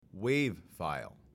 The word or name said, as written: wav file